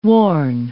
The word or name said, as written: warn